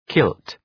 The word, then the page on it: kilt: kılt